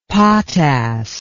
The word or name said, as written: potass